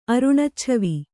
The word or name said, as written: ಅರುಣಚ್ಛವಿ